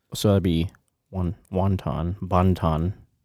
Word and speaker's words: Bonton: Bonton? B-O-N-T-O-N? – Mm-hmm.